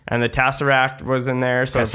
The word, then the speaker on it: Tesseract: You might want to say it right. – That's okay. – This one's for you, Mike. Tesseract. There you go.